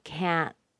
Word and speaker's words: can't: Where do you hear the stress? Yes, you hear it on go, but you also hear it on can't. The vowel sound isn't reduced, and the intonation is still high. It's as high as the verb. This is the only modal that is always stressed.